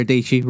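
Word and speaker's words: Dietschy: And then Sarah Deechy, rhymes with Peachy, who is one of my... – Is that the full name of the channel? – No, she's just Sarah Deechy, but Deechy rhymes with Peachy.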